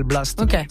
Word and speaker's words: Blxst: Lui il s'appelle Blast. Ça s'écrit B-L-X à la place du A-S-T.